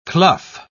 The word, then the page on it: clough: British English